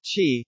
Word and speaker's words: Qi: The Qi standard, pronounced Qi, uses inductive charging.